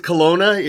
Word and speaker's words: Kelowna: Is it Kelowna? – Yeah, it is Kelowna.